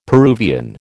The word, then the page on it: Peruvian: American English